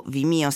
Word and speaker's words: Vimeo: Caricata sul portale Vimeo, o Vimeo se vogliamo rispettare la pronuncia americana, la clip in 10 giorni ha totalizzato 2 milioni di visite in tutto il mondo.